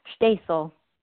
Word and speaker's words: Shteysel: Shteysel.